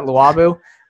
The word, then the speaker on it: Luobu: It's going to be called Luobu or something like that, Luobu. It's spelled L-U-O-B-U if you want to research it.